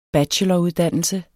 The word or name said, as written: bacheloruddannelse